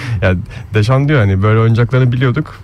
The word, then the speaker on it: Deschamps: Yani bunu Türk spikerler özellikle Fransızca'da böyle E'ler Ö okunur mantığıyla döşan döşan aynen diye vurguluyorlar. O yanlış bir telaffuz, onu bir söyleyelim. İki evet. Saint-Joseph'li olarak orada E'den sonra S'ye geldiğinde onu Deşan diye okumak gerekiyor.